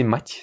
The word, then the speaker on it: мать: Pour bien prononcer мать, si tu veux, tu prends le prénom français Mathieu. Donc Mathieu, et tu enlèves le E à la fin, la syllabe E, pas le E, la lettre.